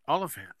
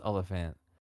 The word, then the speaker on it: Olyphant: Well, it's like elephant, but Olyphant. – But there's an M in there. – An M? – Yeah. – Okay, hold on. – I thought. Let me, hold on. – Now I'm going to have to Google it, because maybe they spelled it wrong. – Oh, they did. They did spell it wrong. It is Olyphant.